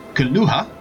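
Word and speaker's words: K'lahu: It's K apostrophe L A H U. – Okay. Yep. – Sounds like Kalua to me.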